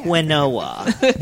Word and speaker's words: quinoa: It's actually pronounced quinoa.